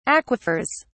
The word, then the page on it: aquifers: British English